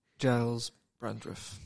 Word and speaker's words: Giles Brandreth: I butchered that. That's so American of me. – Giles Brandreth. – Yes. – Giles.